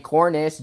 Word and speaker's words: Cornish: My page is just at Ricky Cornish TV. So if you just Google my name, and it's spelled with a Y, R-I-C-K-Y, and then last name Cornish, just like it sounds, C-O-R-N-I-S-H.